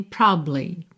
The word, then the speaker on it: probably: Então, probably, mas para algumas pessoas é difícil, e aí você tem essa grande ajuda, que é a possibilidade de simplesmente comer a sílaba do meio, e você tem probably.